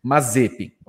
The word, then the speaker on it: Mazepin: Perguntei para ele, aí, qual é a pronúncia desse rapaz? Ele falou, Mazepin.